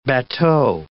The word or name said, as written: bateau